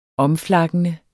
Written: omflakkende

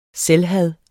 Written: selvhad

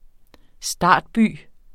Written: startby

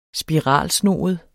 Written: spiralsnoet

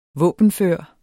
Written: våbenfør

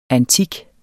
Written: antik

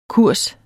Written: kurs

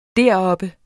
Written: deroppe

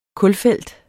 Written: kulfelt

